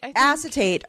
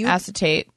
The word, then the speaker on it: acetate: So they're made from acetate. I hope I'm pronouncing that correct. – Acetate. – Okay. – Acetate. That's what I would say. – Okay. A-C-E-T-A-T-E.